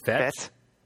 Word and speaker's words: fete: F-E-T-E. – Fett? – Is that how you pronounce that?